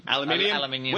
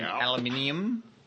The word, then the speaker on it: aluminium: I heard that they've made big steps in making transparent aluminum. – Aluminium. We're Australians, and we say it correctly. Thank you. Take that, Americans. – Aluminium.